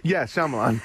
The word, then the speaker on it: Shyamalan: Sham- oh God, I can never get his name right. Shyamalan. yes. – Yeah, Shyamalan. Yeah.